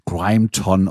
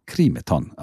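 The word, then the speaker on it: Grimeton: Ja, es wird genau Krimeton ausgesprochen und nicht anders.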